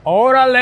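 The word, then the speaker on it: Órale: So the way to pronounce it is Orale!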